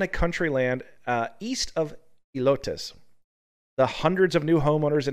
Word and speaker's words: Helotes: As drivers unfamiliar with the area, that might sound extreme, but at Helotes, is that how you say it? Helotes? – Elotes. – Elotes. – Yeah, Elotes.